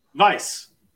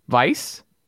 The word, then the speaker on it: Weisz: How do you pronounce her last name? – Vice. – Vice? – Yeah. – Okay. It's, you know. – Vice. – It's European.